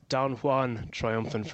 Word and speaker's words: Don Juan Triumphant: It's Don Juan Triumphant for me as well, Bruce. – Beautifully pronounced there. – Thank you very much. – Little French lesson.